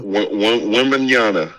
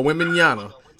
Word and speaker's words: Wembanyama: Wembanyama. – There you go, Wembanyama.